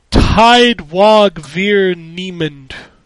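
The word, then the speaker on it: Tyd Wag Vir Niemand: Tidewag Vir Niemand. – Okay, hold on. Spell that for me. – T-Y-D is the first word.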